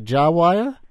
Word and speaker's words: JiWire: How do you say it? You say Jiwire? – Jiwire, yes.